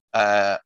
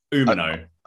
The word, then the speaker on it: Umino: But for me, Umino, how would you say it? Umino.